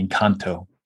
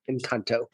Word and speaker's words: Encanto: Or I'm sorry, I pronounced that wrong, didn't I? Encanto. – Encanto. Very good.